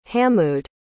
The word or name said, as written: hamood